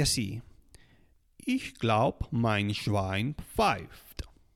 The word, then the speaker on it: pfeift: Ich glaube, mein Schwein pfeift. Mm, pfeift es, un poco difícil de pronunciar para nosotros españoles porque empieza con una P y le sigue una F.